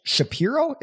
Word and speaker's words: Shapiro: An hour and a half long interview with him and Ben Shapiro.